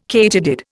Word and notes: katydid: Indian

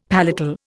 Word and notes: palatal: Indian

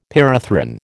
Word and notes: pyrethrin: American English

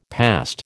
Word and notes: past: American English

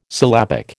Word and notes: syllabic: American English

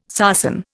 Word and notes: sarsen: Indian

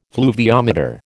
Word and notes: fluviometer: American English

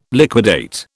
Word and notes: liquidate: British English

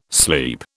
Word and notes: sleep: British English

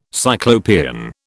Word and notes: Cyclopean: British English

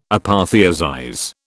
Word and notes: apotheosize: British English

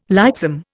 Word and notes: lightsome: Indian

